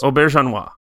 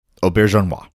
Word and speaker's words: Auberjonois: Auberjonois. – Thank you. – Yeah. I had to practice that when he came on as Odo in Star Trek Deep Space Nine. – Auberjonois.